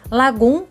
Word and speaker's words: Lagom: Lagum se escreve L A G O M.